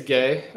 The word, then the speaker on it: Gueye: And the last one, which is probably the most intriguing one to me, is Muhammad Gay? I'm not sure how to pronounce that. – I think it's Gay. That's how Maxwell pronounced it. – Yep. It's spelled G-U-E-Y-E.